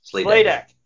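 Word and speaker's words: Sladek: And so number five is in a letter to John Slandek, who's a... – Slaydeck. – Of course I pronounced it wrong.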